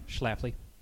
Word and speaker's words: Schlafly: Chafley. Summer lager. – Wait, pronounce that again. – Chafley. – Chafley. – Schlafly. Ah. I like shrimp with my Chafley.